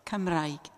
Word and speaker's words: Cymraeg: Cymraeg.